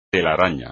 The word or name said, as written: telaraña